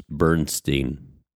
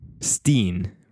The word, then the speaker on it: Bernstein: S-T-I-N? That wouldn't... – So it's spelled, so most people think, and I know by the way you're pronouncing it, say that again, Steen? – Bernstein. – Steen, right? Like, mm-hmm. Like if you were to spell it phonetically, you would say S-T-E-E-N, right? – Yeah, something like that.